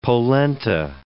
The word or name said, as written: polenta